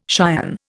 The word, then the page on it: cion: Indian